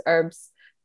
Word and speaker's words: herbs: And then the other thing too is herbs, turn it. Darn it. – Pick me up every time you say that. – Ooh. We don't pronounce the H.